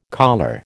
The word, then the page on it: colure: American English